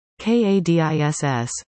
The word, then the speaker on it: Cadiz: Bonnie contributed several Ohioisms for us to consider, including, Cadiz, Spain, Cudiz, Ohio, Kadiss, Lima, Peru, Limuh, Ohio.